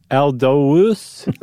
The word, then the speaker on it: Aldous: How are you pronouncing that? – Huxley? – Not the first name. – Aldous? – Aldous? – What do you pronounce it as? Deletrius? – I think you're swapping the U and the O. – Aldous? Aldous? – I think it's Aldous.